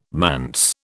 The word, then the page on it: manse: British English